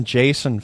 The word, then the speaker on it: JSON: Built-in JSON functions. – Mm-hmm. That's you pronounce that JSON, not JSON, huh? I wonder JSON. which is correct there. – That's a J and then son.